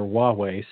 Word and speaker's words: Huawei: And, but there's an H. Now we don't hear the H, we just hear Huawei. So I think Americans would spell that thinking there's a W there, but there's not, it's an H, H-U-A.